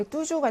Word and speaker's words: toujours: 사실 여기 뚜주 같은 경우나 뭐 그렇죠. 뚜주 같은 경우처럼 이렇게 S가 있거나 없거나의 차이가 없이 그냥 발음은 똑같아요.